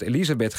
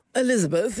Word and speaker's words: Elizabeth: In Nederland heeft ze gewoon altijd Elisabeth geheten, op zijn Nederlands uitgesproken en geschreven. Waarom zeggen jullie nu al dagen ineens Elizabeth? – Elizabeth, ja.